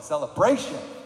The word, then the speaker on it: celebration: So you have to say it like that with the emphasis on the bration part. Celebration.